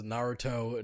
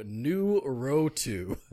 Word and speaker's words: Naruto: Also, Michael B. Jordan pronounces Naruto Nuroto.